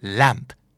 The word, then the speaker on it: lamp: ramp และ lamp สะกดเหมือนกันเปียบเลยยกเว้นเปลี่ยนตัว R เป็นตัว L ตัว L เป็นตัว R นะครับทางลาดคือ R-A-M-P